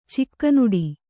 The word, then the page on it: ಚಿಕ್ಕನುಡಿ: Kannada